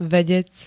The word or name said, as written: vedec